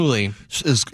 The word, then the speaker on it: Shmuley: Because it's the rabbi, is it Shmuley? Am I pronouncing that right? – Yeah, Shmuley.